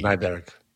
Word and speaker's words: Kniberg: Is it Nieberg? Nieberg? K-N-I-B-E-R-G. – Nyberg. – Nyberg.